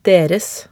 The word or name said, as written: deres